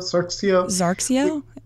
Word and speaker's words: Zarxio: I mean, the drug specifically they're talking about is Sandoz how do you pronounce this? Sarxio? – Zarxio? – Z-A-R-X-I-O.